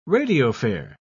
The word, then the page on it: radiophare: British English